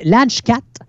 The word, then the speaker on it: leg godt: C'est Ladge Cat qui s'écrit L-E-G, G-O-D-T. Donc c'est comme Legot, mais ça se ah, prononce okay.